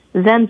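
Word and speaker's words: Xen: And that's Zen spelled with an X, X-E-N.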